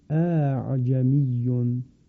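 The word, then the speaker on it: أأعجمي: برای راحت تر شدن تلفظ اون همزه دوم به تسهیل خوانده می شود، اعجمیون.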